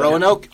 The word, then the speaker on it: Roanoke: Is Roanoke the way you pronounce that? I was told that. Roanoke? 100%. – It is.